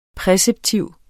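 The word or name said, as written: præceptiv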